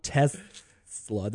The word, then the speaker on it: Tesla: It's not Tesla. There's, it, if it was Tesla, there'd be two S's. – What? – Yes. Let me ask you this. – Tesla.